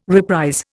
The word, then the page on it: reprise: Indian